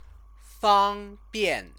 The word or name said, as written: fangbian